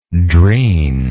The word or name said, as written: drin